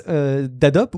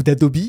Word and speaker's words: Adobe: Écoute, on va commencer sans autre forme de procès, à parler d'une nouvelle prouesse d'Adobe ou d'Adobe, là aussi comment en fonction de comment on veut le prononcer, à la française ou à l'américaine, mais la société éditrice de Photoshop, Illustrator, qui fait du deepfake, Guillaume.